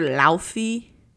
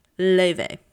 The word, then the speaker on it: Laufey: If you call her Laufey or Laufey, get out of my podcast. It's time for you to leave. Her name is pronounced Leve. It's not actually. It's Icelandic, and I don't know how to say it properly. But she says the American pronunciation is Leve.